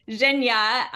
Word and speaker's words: Zhenya: You're actually pronouncing it perfect. So it's Zhenya, the same as you just pronounced it.